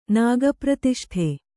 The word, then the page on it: ನಾಗಪ್ರತಿಷ್ಠೆ: kannada